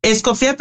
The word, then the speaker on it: Escofía: ¿Es Escofi? ¿Cómo pronuncio tu apellido? Perdón. – Escofía, pero como gustes pronunciarlo, porque lo conocían de mil formas.